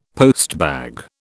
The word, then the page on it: postbag: British English